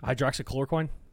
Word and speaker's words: hydroxychloroquine: Hydroxychloroquine? – Hydroxychloroquine was last year.